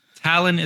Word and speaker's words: Talan: Talan is spelled T A L A N, uh, so not O N. It's going to be A N.